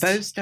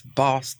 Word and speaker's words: Bost: It's Bost up north. It's Boss down here.